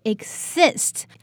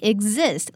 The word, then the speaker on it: exist: Many people will pronounce this word as exist. But the correct one is actually exist. There's a slight z sound here, so it's less air and more stress.